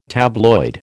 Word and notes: tabloid: American English

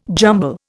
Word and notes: jumble: Indian

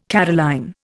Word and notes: Caroline: Indian

